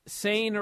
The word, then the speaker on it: Sane: At the Mae Young Classic, they said, Sane rhymes with Lane.